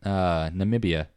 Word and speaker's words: Namibia: Not really for this one, no. It's pretty much all in Namibia. – Weird. – Namibia. – That's how it's pronounced.